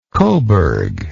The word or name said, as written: cobourg